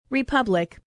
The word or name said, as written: republic